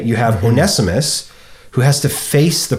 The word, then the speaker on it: Onesimus: Onesimus. – Onesimus.